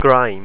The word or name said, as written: greim